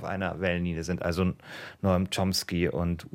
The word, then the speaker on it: Chomsky: Komsky, spricht man ihn aus? Ähm, Chomsky.